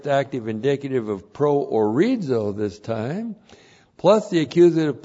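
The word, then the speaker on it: pro orizo (προορίζω): That's how it looks in the Greek, P-R-O-O-R-I-Z, long O, pro orizo.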